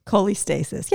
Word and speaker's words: cholestasis: Cholestasis. Yeah, you said it right.